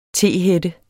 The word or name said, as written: tehætte